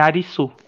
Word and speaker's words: Arisu: é, é, é, que a pronúncia em japonês é a mesma coisa que Alice. É Arisu. – Ah, Arisu.